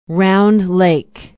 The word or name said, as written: Round Lake